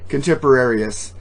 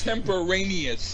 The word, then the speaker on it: contemporaneous: His contemporaneous, or whatever. How do you say that word? – His contemporary, is it? – Contemporaneous. Oral skills during a podcast are far less accomplished. That's contemporaneous.